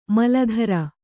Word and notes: ಮಲಧರ: mala dhara

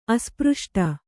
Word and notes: ಅಸ್ಪಷ್ಟ: kannada

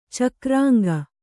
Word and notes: ಚಕ್ರಾಂಗ: cakrāŋga

